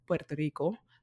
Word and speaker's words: Puerto Rico: Puerto Rico.